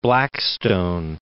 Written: Blackstone

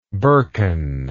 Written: birken

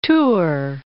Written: Tours

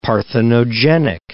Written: parthenogenic

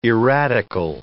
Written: erratical

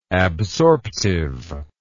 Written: absorptiveness

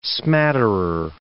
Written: smatterer

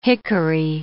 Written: hickory

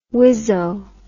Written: wizzo

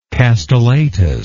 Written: castellatus

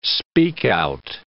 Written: speak-out

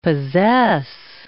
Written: possess

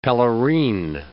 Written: pelerine

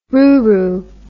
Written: ruru

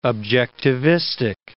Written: objectivistic

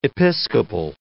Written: Episcopal

